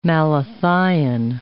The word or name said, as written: malathion